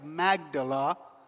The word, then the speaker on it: Magdala: And he forgave a woman by the name of Mary Magdalene, or Mary of Magdala. That's the right way to say it.